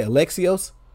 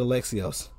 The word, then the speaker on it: Alexios: And that's why they added, how do you say, Alexios? – Yes, that's why they added Alexios.